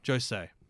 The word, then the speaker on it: Josei: I always say that wrong. I'm so bad with my pronunciation. Josei. It's a very Josei series, i.e.